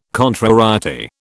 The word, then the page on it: contrariety: British English